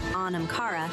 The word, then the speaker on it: Anamkara: In Irish, Anamkara, pronounced Anamkara, means soul friend.